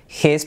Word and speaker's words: his: Lo mismo que of his, podemos saltar la H, of his hand.